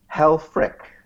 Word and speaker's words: Helfrich: Helfrich. – Helfrich. – It's spelled with a CH, but it's pronounced with a K.